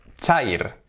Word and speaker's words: chair: ¿Y qué palabras tenemos que se escriben también con air, esa palabra? Pues tenemos aire, air, que se pronuncia air. O tenemos pelo, que se pronuncia hair. Por lo tanto, air, hair, silla, chair. Ahí está, hair, air, chair.